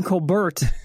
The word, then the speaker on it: Colbert: But we do pronounce the T if it's left up to me. – Okay. Well, Stephen Colbert is with us, just to clarify.